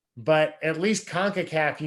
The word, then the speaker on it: CONCACAF: It's CONCACAF, right? – You got it. Nailed it.